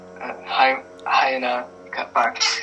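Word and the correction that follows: Huayna Capac: Correct me if I pronounced that wrong, but go ahead. – Juana Capac, he was a really interesting guy.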